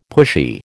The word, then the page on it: pushy: American English